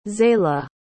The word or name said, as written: zaila